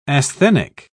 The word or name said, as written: asthenic